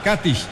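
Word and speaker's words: Katić: O Katish, ellos le ponen el acento en la A. Katish, Nikola Katish.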